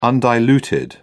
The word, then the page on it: undiluted: British English